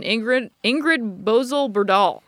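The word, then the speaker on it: Ingrid Bolsø Berdal: And it stars Dwayne The Rock Johnson, John Hurt, Ian McShane, Joseph Fiennes, and Ingrid Bozell-Berdahl. I don't know if I'm pronouncing that correctly. – Sounds right to me.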